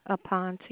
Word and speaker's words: Aponte: Aponte. – Okay. Okay. Spell that for me, please. – A-P-O-N-T-E.